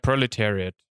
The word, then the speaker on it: Proletariat: Yeah, Proletariat.